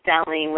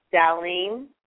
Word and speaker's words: Darlene: This is Stellene with from Florida. – I'm sorry. What was your name? – Stellene. – Spell that. – D-A-R-L-E-N-E.